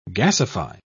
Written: gasify

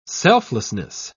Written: selflessness

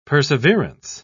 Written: perseverance

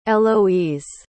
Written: aloise

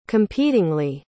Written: competingly

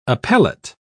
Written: appellate

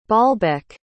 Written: baalbek

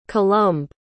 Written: colombe

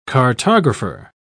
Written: cartographer

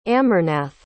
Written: amarnath

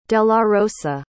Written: delarosa

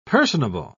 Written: personable

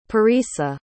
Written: parisa

